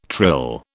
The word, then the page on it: trill: British English